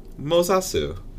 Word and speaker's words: Mozasu: It's like is it like Moses? – Maybe, but I'm I'm just pronouncing it the way it's said in the audio book. I know. Interesting. Okay. So Mozasu.